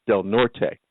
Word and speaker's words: Del Norte: Now, I will explain to you that that is spelled D-E-L-N-O-R-T-E, which should be pronounced Del Norte. Right, because Becky will probably tell me yes, but they pronounce it Del Norte. – Okey-dokey. – I'm not sure why. I'm having a feeling so they don't feel so Hispanic. – Yeah, possibly.